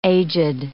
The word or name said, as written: aged